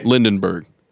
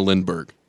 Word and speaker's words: Lindbergh: That's the elitist reporter way of correcting that you mispronounced his name, Matt. – What did I say? – Lindenberg instead of Lindbergh. – Oh, Lindbergh? – Yeah.